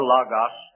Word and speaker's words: Logos: In Hebrews chapter 4 and verse 12, the Word of God, the Word of God is the word Logos, or Logos, as some would pronounce it, Logos.